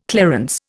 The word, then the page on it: clarence: Indian